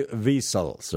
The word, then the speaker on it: Wiesel: The Elie Wiesels, or Wiesels, but it's actually Wiesel is the correct pronunciation according to the German, but I've heard it both ways.